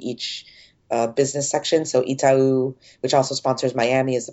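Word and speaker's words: Itaú: Is, is also that how you pronounce that word? – Mm-hmm. I never would have gotten that right. – Yeah. Oh, Itau. Itau. Okay. Yeah, Itau. I would have said like Itau.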